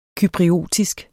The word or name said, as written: cypriotisk